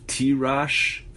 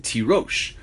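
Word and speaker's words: Tirosh (תירוש): Rav Kahana Ramiksiv Tirash Vikarina Tirosh. The Torah writes the word Tirash, right? The word for wine. But we pronounce it Tirosh.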